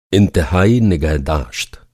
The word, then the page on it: इंतहाई: انتہائی